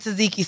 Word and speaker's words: tzatziki: Nah, like, I think overseas, it's tzatziki or something. Like, they don't pronounce the T, bruh.